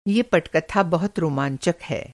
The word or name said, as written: रोमाँचक